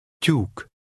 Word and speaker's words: Tyúk: In the Hungarian alphabet, this is one single letter and is pronounced like a fast T-Y, as in the combination of the two English words at your. Listen and repeat number 1. – Tyúk.